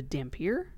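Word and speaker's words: dhampir: Now, another type of vampire, and it's spelled similar to vampire, the dampier? – Mm-hmm. – Is that how you say it? – Yes.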